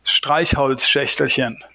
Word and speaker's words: Streichholzschächtelchen: The word is Streichholzschächtelchen.